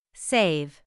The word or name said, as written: save